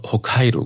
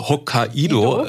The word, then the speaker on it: Hokkaido: Eben nicht Hokkaido heißt, so wie wir es halt bei uns aussprechen würden, sondern also es kann man ja letzten Endes nennen, wie man uns sagen, wie man will, aber richtig, wie du es gesagt hast, Hokkaido.